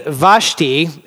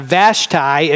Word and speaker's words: Vashti: Queen Vashti is how we could pronounce it, or Vashti if you're from the south.